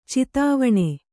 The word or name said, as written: ಚಿತಾವಣೆ